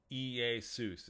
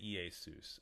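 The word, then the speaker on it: Iesus (Ἰησοῦς): Iesus is how you say it. E-E-A-Y-S-O-O-C-E. That's where you get Jesus. The transliteration is L-E-S-O-U-S, but you say it Iesus, okay?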